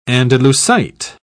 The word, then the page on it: andalusite: British English